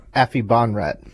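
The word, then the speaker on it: Aphibarnrat: Afi Bonrat.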